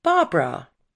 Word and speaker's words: Barbra: Winning a talent contest in a gay bar started her career, and disliking her name, she changed it from Barbara to Barbra, like bra.